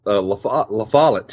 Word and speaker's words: La Follette: Well, I grew up in a little town called La Follette, Tennessee. L-A and then space, capital F-O-L-L-E-T-T-E. But a lot of people pronounce it La Follette.